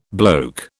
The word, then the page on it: bloke: British English